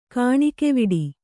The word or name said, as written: ಕೆಣಕುವಡು